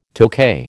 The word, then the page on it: Tokay: American English